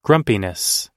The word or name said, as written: grumpiness